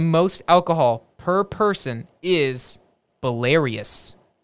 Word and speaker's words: Belarus: No, I just said Belarus. It's Belarus. That's how you pronounce that. – Belarus.